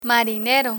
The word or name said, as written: marinero